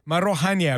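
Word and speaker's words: Maarohanye: Bail granted to Mulimo Jubjub Maharonyane. – Marohanya, there beg your pardon. Thank you, Nkosikona Malinga Mnisi, helping me out there with the pronunciation.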